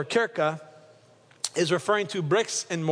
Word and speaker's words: Kirche: That word church is not the German word in the 15th century, which we phonetically look at it and it's pronounced in our language, Kirch. But Richard, Pastor Richard, has been to Germany several times and he came to me and said, that's not how they say it in German. They say it like Kirche.